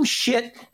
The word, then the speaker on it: Schiff: Yeah. Adam Schiff. – No, it's Schiff.